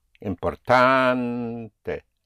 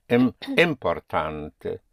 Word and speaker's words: importante: Importante. Yes. So you cannot say importante.